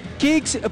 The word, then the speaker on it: Kixx: ¿pero cómo se escribe Kicks? Se escribe K-I-X-X. Kicks, protección que se siente.